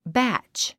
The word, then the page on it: batch: bǽtʃ; バァッチ